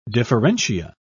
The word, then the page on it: differentia: British English